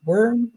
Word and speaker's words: Wurm: Wurm? – Yeah, awesome.